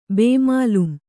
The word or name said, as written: ಬೇಮಾಲೂಮ್